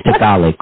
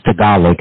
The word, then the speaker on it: Tagalog: Well, thank you so much for correcting me on that pronunciation, Tagalog. So, because I've always heard them say, because I always have watched boxing telecasts where they featured Filipino fighters and they had the interpreter, and they always pronounced it how I pronounced it before. But thank you for giving us the correct pronunciation, which is Tagalog.